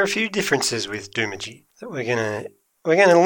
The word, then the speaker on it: Doomadgee: Somebody else who's launching this weekend, we've got Doomadgee in Queensland. – Now, what do you know about Doomadgee? – What I know is that everybody else pronounces it Doomadgee, but it's actually Doomadgee.